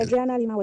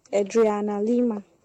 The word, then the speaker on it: Adriana Lima: That beautiful model, Adriana Lima. I don't know if I'm even pronouncing the name correctly. – You are pronouncing the name correctly, but last time I checked, Adriana Lima is my birthday mate.